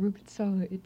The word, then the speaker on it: Rübezahl: Rübezahl, how do you say Rübezahl? – Rübezahl, it translates to the turnip counter in English.